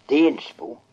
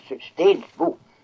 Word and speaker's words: Steningsbo: Men eller så säger vi här Stensbo istället för Steningsbo. – Jaha. – Det är uttalet Stensbo. – Ja. – Nis höres inte.